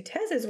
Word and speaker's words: Tez: She did let off that she had met a guy named Tez, which it will get very confusing with Tez. – Well, and Tez is spelled like what? T-E-Z? Tez? – T-E-Z.